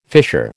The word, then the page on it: fissure: American English